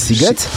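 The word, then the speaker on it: Sziget: kojarzysz, że pisze się Shiget, a wymawia się Siget?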